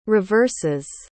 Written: reverses

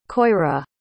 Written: coira

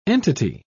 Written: entity

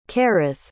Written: kerith

